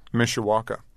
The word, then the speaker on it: Mishawaka: Mishawaka. – In Indiana. Is that a small mm-hmm. town or a suburb? – Yeah, it's 40,000 people. – Mm-hmm. And describe your neighborhood for us. – Mishawaka, there was like a big Unoro plant that was in the 50s that closed down, I think, in the 70s.